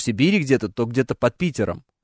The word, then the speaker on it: Питером: А где изменения-то ударения? – В пятером. – А в, как надо? В под Питером. Правильно, конечно же.